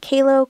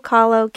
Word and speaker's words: Kahlo: Kahlo, Kahlo, K-A-H-L-O.